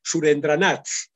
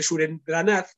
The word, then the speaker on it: Surendranath: Entonces hay un sargento muy singular, el sargento de nombre impronunciable, Surendranath Banerjee es el apellido, pero es curioso porque Surendranath suena en inglés como surrender not, es decir, que no se rinde, entonces lo llaman constantemente con ese apodo, ¿no?